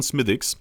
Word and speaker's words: Smithwick's: Geschrieben Smithwick's, aber ausgesprochen Smithwick's.